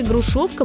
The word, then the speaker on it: грушовка: Есть такой старинный русский сорт народной селекции – грушовка московская. Ударение на втором слоге.